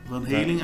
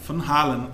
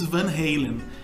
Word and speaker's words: Van Halen: Aí tem até uma característica, porque como o Ed e o Alex, que é o irmão dele, baterista, são holandeses, a pronúncia lá na Holanda é Van Halen. Van Halen. Van Halen. Van Halen. E nos Estados Unidos, Van Halen. E no Brasil, Van Halen, né? Que é totalmente errado.